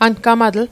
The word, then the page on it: angamatl: ankamatl